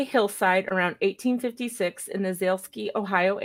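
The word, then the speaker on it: Zaleski: Yeah, Zalesky, like Zalesky. The diamond people.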